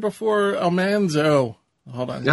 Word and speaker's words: Almanzo: No, I didn't learn that until one of my lives where somebody commented and said it's pronounced Almanzo.